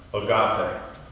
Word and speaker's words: agape: It's a Greek word for love, agape, sometimes it's pronounced agape.